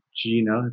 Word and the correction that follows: Gianna: if I pronounce their names right. – Yeah, Kiara and Gianna.